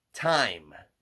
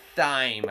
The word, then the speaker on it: thyme: Time. – Really? – Yeah, not T-I-M-E. – I know. – T-H-Y-M-E. – I've been in the kitchen. – Thyme, I think it's pronounced.